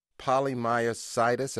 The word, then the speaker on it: polymyositis: Polymyositis, am I saying it right? – You did very well.